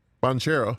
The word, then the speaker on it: Banchero: Banchero? – Banchero.